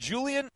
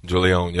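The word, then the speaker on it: Julién: And, of course, Mark had to get to the bottom of Julian or Julione. – Joining us right now in the Hyundai Texans radio studio, it's Julione Davenport. Now, did I pronounce that correctly? – Julione, yep.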